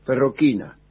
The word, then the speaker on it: Ferrochina: Y entonces se escribe ferrochina, ferroquina, Bisleri era el fabricante, ¿no?